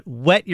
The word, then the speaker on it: whet: And by the way, didn't know this until I misspelled it on Twitter and got chastised for it years ago, but whet your appetite is not spelled W-E-T. It is not. It is whet your appetite, W-H-E-T. Right? So you almost pronounce it like you do, what's the Family Guy family episode? Cool whip, right? It's whet your appetite.